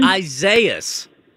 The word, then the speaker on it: Isaias: Isaias.